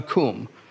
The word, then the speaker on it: Qum: This rug is a koom. It sounds like K-O-O-M, koom. It's spelled Q-U-M.